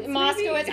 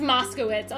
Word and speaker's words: Moskowitz: Bobby Violante, I think, 20 years old, and Stacy Mokoski? How do you say that one? – Moskowitz. – I butchered that. It's Moskowitz. Oh my God.